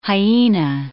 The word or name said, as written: hyena